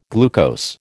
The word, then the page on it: glucose: American English